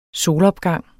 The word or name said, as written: solopgang